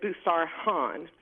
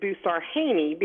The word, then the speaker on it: Busarhaney: So the funny joke is that my husband is from Morocco, so he pronounces it Busarhan. I pronounce it Busarhaney because at the time in the South, trying to learn how to spell it and say it, it was easier for me to pronounce it phonetically.